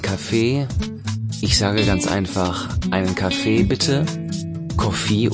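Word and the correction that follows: Kohi (コーヒー): Acht hier genau auf die Aussprache. Auch wenn es ein englisches Wort ist, sagen wir Kohi. – Ah, Kohi.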